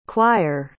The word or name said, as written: choire